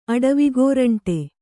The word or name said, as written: ಅಡವಿಗೋರಂಟಿ